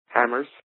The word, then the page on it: hammers: American English